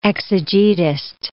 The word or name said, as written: exegetist